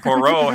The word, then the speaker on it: Poirot: P-O-I-R-O-T, but it's she told me it's pronounced Poirot.